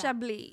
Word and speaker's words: Chablis: Chablis. It's like the alcohol. Lady Chablis. – In my head, it was Chablis. – All right. Have you never heard of the alcohol Chablis? – No.